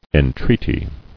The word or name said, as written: Entreaties